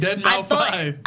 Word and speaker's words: Deadmau5: Do you not pronounce down. The S in Deadmau5? – There's no S. It's a 5. – That's what I'm saying. – That's why I said Deadmau5.